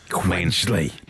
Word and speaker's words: Quedgeley: I don't know if I'm pronouncing this right. It's Quedgeley Wanderers Football First Team. They're a team based in Gloucester. – Quedgeley.